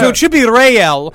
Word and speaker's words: Rayel: And the reason why I keep saying Rael is because he pronounces it Rael, but it's spelled R-A-Y-E-L.